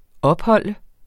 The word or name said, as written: opholde